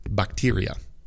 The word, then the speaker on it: bacteria: Segundo es bacteria. Bacteria, okay, bacteria. Casi lo pronuncié como en español, pero bacteria and data.